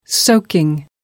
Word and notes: soaking: American English

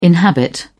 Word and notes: inhabit: British English

